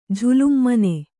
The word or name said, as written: ಜುಲುಮಾನೆ